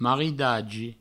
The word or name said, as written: maridagi